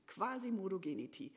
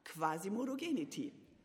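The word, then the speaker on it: Quasimodogeniti: Und um deinen mangelnden Lateinkenntnissen, geschätzter Kollege Lars, etwas auf die Sprünge zu helfen, das ist die richtige Betonung. Quasi Modogenity. Und als langjährige Moderationstrainerin habe ich wunderbar gerne diesen Sonntag als Beispiel genommen. Probiert doch mal. Quasi Modogenity. Quasi Modogenity.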